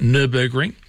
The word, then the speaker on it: Nürburgring: And just for those of you who are listening to me reading the feedback on Nürburgring, people telling me how to pronounce it, Nürburgring.